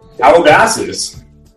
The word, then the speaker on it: Calabasas: Is that how you say it? Calabasas? – Calabasas. – So it's like Calabasas.